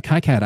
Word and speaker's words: KiCad: Yeah, KiCad.